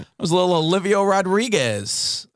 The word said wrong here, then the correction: Olivia Rodrigo: Is that not right? Olivia Rodrigo. – You got to drop that last syllable. Like, pretend it's a Grammy. – Olivia Rodrigo.